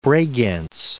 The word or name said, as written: Bregenz